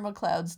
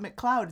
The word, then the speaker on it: MacLeod: He was Connor MacLeod's descendant somehow. – And you know what the fun part is? That's not how you pronounce that name in Scottish. It's what? – Not MacLeod. It's MacLeod.